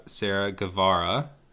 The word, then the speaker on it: Sarah Guevara: We have Sarah Guevara.